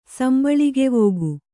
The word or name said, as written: ಸಂಬಳಿಗೆವೋಗು